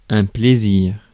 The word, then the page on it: plaisir: French